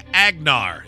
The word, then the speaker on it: Agnar: The answer is King Agnar. Is that how you say it? – I believe so, yes.